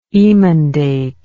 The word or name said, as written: emendator